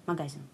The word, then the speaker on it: магазин: А магазин один, магазин. – Магазин? Магазин. Правильное, магазин. правильное ну, помольское ударение – магазин.